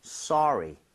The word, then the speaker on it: sorry: 대신에 발음할 때 소리는 좀 심하죠. 소리는 아니니까 써에 가깝게 입모양 살짝만 벌려주고 발음했으면 좋겠어요. 그래서 yes. – 소리 이렇게 하지 마시고요. Sorry, sorry.